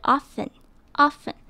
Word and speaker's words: often: You are probably busy, or I'll let you go.首先我们来看看 often 这个字， often 的 o 记得要念得短短扁扁的，嘴巴不要闭起来，听起来会像是 o，中间的 t 不发音，所以整个字念起来会是 often often。